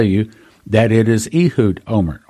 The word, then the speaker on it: Olmert: Ehud Olmert's last name is spelled O-L-M-E-R-T. So the American tongue would pronounce that Olmert. But that's not the way they pronounce it in Israel, and I've heard several people in Israel pronounce his name. They pronounce it Omer, which is the same word that she heard.